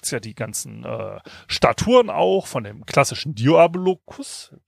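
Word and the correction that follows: Diskobolos: wie spricht man das aus? Disco Bolus.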